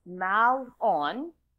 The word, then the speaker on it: Mahlon: Mahlon, I'm trying to repeat, I go to these things on how do you pronounce his names, but it's Mahlon.